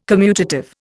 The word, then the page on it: commutative: Indian